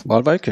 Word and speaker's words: Waalwijk: Und dann hatte ich eigentlich auf dem Schirm, dass ich Waalwijk, stimmt es, die Aussprache? – Waalwijk, ja.